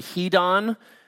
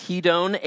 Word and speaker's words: hedone: In Greek, that word passions is the word hedon, or perhaps pronounced hedon, H E D O N E, and it means a carnal or a sensual desire, unholy desires expressed in an unholy way.